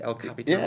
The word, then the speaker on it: El Capitan: El Capitan, or am I saying that right? El Capitan. – Yeah, yeah. El Capitan.